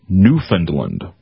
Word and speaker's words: Newfoundland: So we got confirmation from somebody who knows. – Mm-hmm. – That I was saying it correctly, that it is Newfoundland.